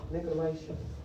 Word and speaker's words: Nicolaitans: And I'm not saying the name right. It's in the book of Revelations. – Nicolaitans. – Thank you. Thank you.